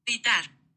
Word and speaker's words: gritar: Gritar. – What? Hold on. What? – Gritar. – Gritar. Gritar. What? How did? – Gritar. – Gritar. Okay, so she doesn't really say the last R. Gritar.